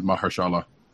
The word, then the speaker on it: Mahershala: how to pronounce his name, and it is Mahershala. – Mahershala. – Is that Cottonmouth? – Or it's Mahershala, actually, is the way I heard it pronounced.